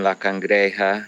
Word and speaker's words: La Cangreja: I don't know how to pronounce that one, but La Cangreja, is that about it? Or? – Yeah, that's correct. Our property borders a national park named La Cangreja.